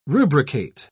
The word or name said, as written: rubricate